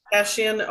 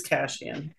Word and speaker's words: Kashian: How are you pronouncing our last name? – Kashian, always Kashian.